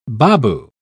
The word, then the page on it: baboo: British English